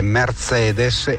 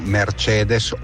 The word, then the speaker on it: Mercedes: Volevo fare i complimenti a Meazza per la perfetta pronuncia, si dice BMW e non BMW, come si dice Mercedes e non Mercedes o Mercede.